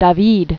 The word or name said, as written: Jacques Louis David